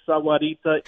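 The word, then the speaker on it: Margarita: A man who consistently reminds us that the word Sawarita has four syllables, not three.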